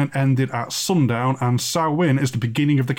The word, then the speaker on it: Samhain: It looks like it's spelt as Samhain, but it's pronounced Samhain, as the native languages of Albion aggressively resist English.